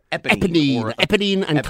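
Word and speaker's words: Éponine: Eponine, Cassette.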